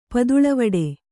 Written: ಪದುಳವಿಡು

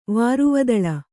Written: ವಾರುವದಳ

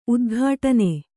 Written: ಉದ್ಘಾಟನೆ

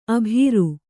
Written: ಆಭೀರಿ

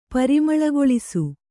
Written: ಪರಿಮಳಗೊಳಿಸು

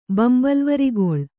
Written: ಬಂಬಲ್ವರಿಗೊಳ್

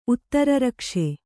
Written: ಉತ್ತರರಕ್ಷೆ

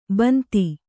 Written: ಬಂದಿ

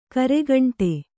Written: ಗರಿಗಂಟಿ

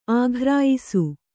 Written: ಆಘ್ರಾಯಿಸು

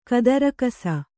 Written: ಕದರಕಸ

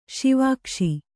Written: ಸಾವಗಿಸು

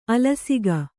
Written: ಆಲಸಿಕೆ

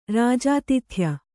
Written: ರಾಜಾತಿಥ್ಯ

